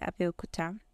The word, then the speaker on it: Abeokuta: And in like 2 different states, Oyo and Abeokuta. Oh, yeah, as an aside, I realized that it's pronounced Abeokuta, not Abeokuta. Like Abeokuta, not Abeokuta. Because it directly translates to under a rock.